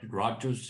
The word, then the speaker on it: Rutgers: Actually, we did an economic study at Rocket University, close to you, and it's, did I pronounce it correctly? – Rutgers University.